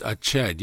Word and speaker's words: Echad: One is Echad. It's spelt in a way that you would almost hear the word Zion. It's Echad, yes.